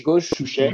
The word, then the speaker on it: gauche touche: How do you pronounce it in French? You say uh, gauche, gauche, touche. – gauche. – Yes, and you can you can say it with an English accent because it's it's uh, it's how it's said in, in fencing, uh, gauche touche. Gauche touche.